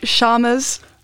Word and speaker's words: Chalmers: and I don't know how to pronounce this, Ch- Chalmers. – Yes, sounds good.